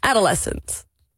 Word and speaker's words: adolescent: Fluorescent, adolescent. – Hoe? – Adolescent is het toch? Ja. Dat is de uitspraak. – Adolescent of adolescent? – Nee, adolescent.